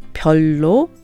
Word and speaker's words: 별로: Now, 별로, in colloquial speech, people pronounce it 별로.